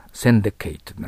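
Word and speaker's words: syndicate: 英語の発音はセンデケートとなります。センデケート。または名詞だったらセンデケットと発音します。センデケット。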